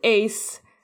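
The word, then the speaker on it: ijs: Ice cream is pronounced Ace in Dutch.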